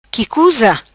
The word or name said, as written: qui cuza